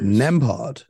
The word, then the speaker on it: Nembhard: Yeah, this kid Nemhard. Nembhard. Is that how you pronounce it? Yeah, yeah, yeah.